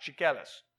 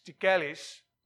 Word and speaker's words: Chiquelis: Now, I'm Greek, and only fellow Greeks and Mexicans can pronounce my name at the first shot, Chiquelis. All right? Chiquelis.